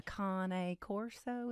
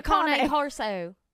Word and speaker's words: Cane Corso: A Cane Corso. Is that how you say it? – Yeah, that's right. A Cane. A Cane Corso.